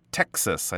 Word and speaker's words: Texas: Texas. I think that word was uh, mispronounced a little bit. It's like tech, like technology right?